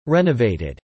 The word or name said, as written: renovated